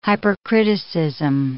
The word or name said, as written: hypercriticism